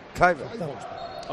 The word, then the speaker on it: Kluivert: Aceptamos Kluivert. – Todo. – Kluivert.